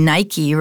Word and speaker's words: Nike: Well, and you gave the correct pronunciation. We say Nike, right? And that's, of course, a shoe brand now with that swish on the side. So you mentioned the wings. Nike, the goddess of victory, right? – And yeah, she has wings.